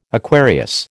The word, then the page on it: Aquarius: American English